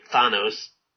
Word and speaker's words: Thanos: is I'll say his name right for everybody, Thanos in Marvel.